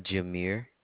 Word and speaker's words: Jameire: Jameer.